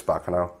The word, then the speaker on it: Baccano: It's pronounced like an Italian word, so I think it's Baccano.